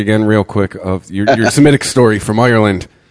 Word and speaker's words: Smithwick's: It says a Smith, I say Schmidt's is how you pronounce it, right? But it's spelled Smithwick's.